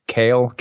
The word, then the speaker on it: Kailh: With- Seven different types of switches, three from Cherry MX and four from how the hell do you pronounce this? Kale, K A I L H. – I would just go with Kale.